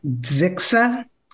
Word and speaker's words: Zixa: I had to talk to some Native American linguists to figure out how to pronounce it. It would be something like Zixa.